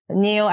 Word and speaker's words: NIO: It's spelled N-E-O? – N-I-O. – N-I-O. – N-I-O, NIO.